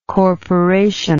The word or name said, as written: corporational